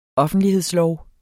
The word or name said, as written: offentlighedslov